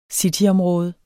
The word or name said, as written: cityområde